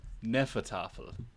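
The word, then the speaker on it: Hnefatafl: Okay, apparently it's pronounced Neffertafel. – Oh, right, yeah. – I have been playing Neffertafel, which is...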